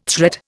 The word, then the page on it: threat: Indian